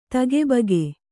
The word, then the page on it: ತಗಿಬಿಗಿ: tagi bigi